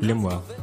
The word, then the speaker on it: Lemuel: Lemuel. – Yeah. Can you spell it? – L-E-M-U-E-L.